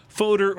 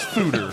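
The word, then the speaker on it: foeder: The world is full of strange mysteries, and we'll explore more of them, including some either fodder or feeder-aged rice lager. – OnStark says pronounce fooder. – Fooder.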